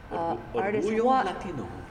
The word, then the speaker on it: Orgullo: Orgullo Latino. – Orgullo. – What does that mean? – The two L's are pronounced like Orgullo Latino.